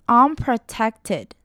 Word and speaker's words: unprotected: 首先要注意到的是 unprotected，它的重音呢是在后半段 ,tected, unprotected, unprotected。